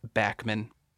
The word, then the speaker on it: Bachman: He was also the drummer in, as we would say in Texas, Bachman Turner Overdrive, even though I think it's actually pronounced Bachman.